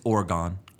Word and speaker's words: Oregon: But unfortunately, there's a lady up in Oregon named Kathy Boone who died. Let me stop you there. It's Oregon. – Oregon. – Come on. – I'm from Texas. Listen. I pronounce it however I want.